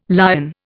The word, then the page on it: Lyon: Indian